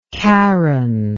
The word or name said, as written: karyn